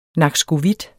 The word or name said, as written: nakskovit